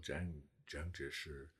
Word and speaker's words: Jiang Jieshi: The head of it, Chiang Kai-shek, or Jiang Jieshi in the Mandarin pronunciation.